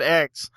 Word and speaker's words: X: Mortal 10. Kombat X. I'm sorry, Ed Boon. – It is the 10th game, but you're meant to pronounce it X.